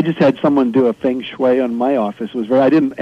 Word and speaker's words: feng shui: And the feng shui, am I pronouncing that correctly? – That's correct.